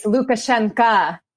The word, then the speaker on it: Lukashenka: Lukashenko. Yes. Quote. – Yeah. If we're going to be like precise, Lukashenka. We say an A at the end, so that we're like supporting Belarusian language people.